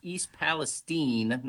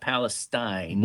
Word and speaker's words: East Palestine: And by the way, it's pronounced East Palestine, not Palestine.